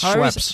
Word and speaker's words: Schweppes: Schweppes? – Yes, Schweppes.